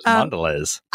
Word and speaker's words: Mondelez: So this is from Jason Levine, who's the North America CMO for Mondelez International. Side note, I did have to YouTube how to pronounce Mondelez. – Mondelez. – That's what I thought too, but apparently it's Mondelez.